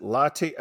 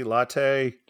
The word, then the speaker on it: Lotte: And then the LPGA event, he pronounce it latte, L-O-T-T-E, latte.